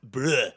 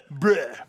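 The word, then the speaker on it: Breaux: Along with Antagonizers ATL, you get Bro, and that's B-R-E-A-U-X from Atlanta. Dial Drive, what's that? – Pronounced bruh. – Bruh. – Bruh. – Dial Drive from Orlando, The Longest Haul, and that's H-A-L-L, The Longest Haul from Tavares, Florida, and then The Aggravated from Atlanta.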